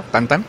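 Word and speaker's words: Tintin: Y efectivamente los que mencionas como Asterix, Mortadelo y Filemón, Tintín, etcétera, Tantan, que realmente es el nombre, es la pronunciación.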